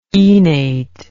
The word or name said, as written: enate